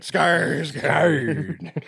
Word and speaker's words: Skarsgård: There's a little circle above the A. That's it. So you have to say it. Skarsgård. That's how you pronounce it.